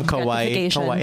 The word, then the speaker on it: Kawhi: And I heard there's also some Kawhi.